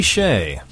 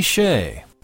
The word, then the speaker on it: Skjei: This is Amy Shea, a composition she titled Autumn Moments. Thank you. That's the New Age pianist from St. Cloud, Minnesota, Amy Shea. And that's spelled S-K-J-E-I.